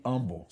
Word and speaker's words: humble: And be humble. See, I don't say humble because the H is silent.